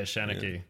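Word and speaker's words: seanchaí: Pronounced Shanachie? – Yeah, Shanachie.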